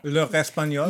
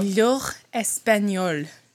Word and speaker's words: L'Heure Espagnole: L'Heure Espagnole. – L'Heure Espagnole.